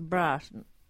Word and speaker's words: Brighton: You got to say it right too. It's Brighton.